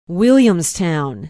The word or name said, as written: williamstown